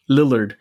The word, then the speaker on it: Lillard: Lillard.